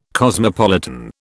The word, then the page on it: cosmopolitan: British English